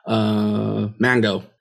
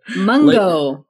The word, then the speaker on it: Mungo: They say mango? – No. I think it's... – Oh, God. – Did you say mango? – What? We're okay, we don't know how to pronounce the name. Okay, all right. – Mungo. Lake Mungo.